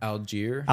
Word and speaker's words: Allgeier: Aguilar, is that how you say his name? The running back? I can't remember. Allgeier. Allgeier, like yeah.